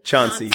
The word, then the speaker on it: Chauncey: Chauncey. – Chauncey. And Lord. – Like, think of Sean. So somebody named Sean. – Sean. – And then think of the ocean. Sea.